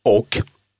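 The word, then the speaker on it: och: «О» – возможен вариант прочтения «ок».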